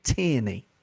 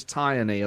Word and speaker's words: Tierney: This is how Joe Cole pronounces Kieran Tierney. – Tierney. – Tierney. – Tierney. Crazy. It's not even... Because at least, you know, if you break it down, if you look at it, it's T-I-E.